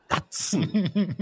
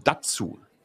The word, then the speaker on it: DAZN: Wer ist denn so bekloppt und gibt seinem seinem Service den Namen Name Datsen oder nennt man das D-A-Z-N, weißt du, so wie ZDF oder ARD, so. Alter, das heißt ja Datsen. Was ist denn das? Oder ist, ist das so wie, wie ganz schnell englisch ausgesprochen ähm, Datsun? Gibt es die überhaupt noch Datsun?